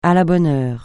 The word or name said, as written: à la bonne heure